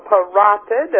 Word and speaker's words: parotid: So parotid is the accent on the second syllable, also called a parotid gland, a salivary.